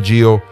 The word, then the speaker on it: Gio: This has been Gio, the youth pastor for LWIM.